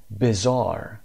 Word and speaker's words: bizarre: حالا یه صفت داریم اینجا اسم bizarre تلفظش هست. بریم چک کنیم. Bizarre در بریتش bizarre چون آر آخر تلفظ نمیشه بعد از صدای آ و گفته میشه bizarre و و امریکن ما میگیم bizarre.